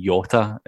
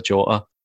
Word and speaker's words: Jota: I had a bit of a go actually at Andy Walker the other day for saying Yota instead of Jota, which I've got a cheek to do, obviously, because I'm sitting here pronouncing another one of our players' names wrongly.